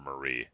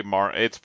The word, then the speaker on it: Marie: They're definitely no Callie or Mari or Marie. I don't know. Do you say Mar? It's spelled Marie. It's spelled Marie. – It's Mari.